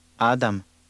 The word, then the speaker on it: Adam: Adam. Adam. – Did you hear the stress in that word? With two-syllable words, the stress is always on the first syllable.